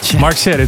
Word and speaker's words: Mark Sherry: This week, Mark Sherry sent me an email that I said his name with a wrong accent. Mark Sherry. Mark Sherry, that's how you say it, right?